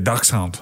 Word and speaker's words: dachshund: Are you saying dachshund? Is that how you say it? – Yeah, dachshund.